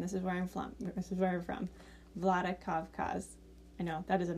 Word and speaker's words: Vladikavkaz: How do you say it again? – Vladikavkaz. – Vladikavkaz? – Yeah.